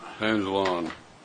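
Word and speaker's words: Hanzelon: Hansel on. – It's like Hansel and Gretel, but Hansel with an on. It's spelled H-A-N-Z as in zebra, E-L-O-N.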